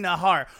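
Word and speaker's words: Najar: And if you say Andy Nahar, before you type in the J that gets pronounced like an H in his name, just turn off whatever device you're using and go to bed.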